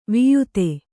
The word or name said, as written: ವಾಯಿದೆ